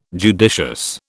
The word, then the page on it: judicious: British English